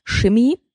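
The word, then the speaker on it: Schimmi: Ähm, der Titel heißt O oh Shimmy mit S-C-H-I-M-M-I. -M -M i m i ähm, so wird er nämlich ausgesprochen, so hat er sich eingedeutscht, so hat seine Mutter ihn genannt.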